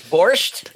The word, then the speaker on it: borscht: I'll be knee deep in borscht. Oh, is that how you say it? Borscht? – Borscht.